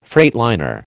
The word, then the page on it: freightliner: American English